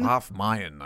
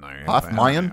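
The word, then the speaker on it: Halve Maen: How do you say it in Dutch? Have Mean? – Half Mayan. – Half Mayan? – Half Mayan.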